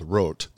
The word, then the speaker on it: roht: including my parents' favorite is something called rot. It's spelled R-O-H-T.